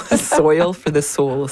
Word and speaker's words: Soil for the Soul: Alors vous allez aussi vous investir, alors on va en parler parce que c'est un projet vraiment intéressant d'art écologique, est-ce que je le dis bien? Donc c'est Soil for the Soul. Excusez-moi, voilà, pour l'accent. – C'est bon, Soil for the Soul, c'est voilà, bien ça. Voilà, vous le dites bien mieux que moi.